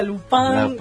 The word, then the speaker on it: Lupin: Yo le digo Lupin. – Bien. – Pero Netflix había hecho toda una campaña de se pronuncia Lupin.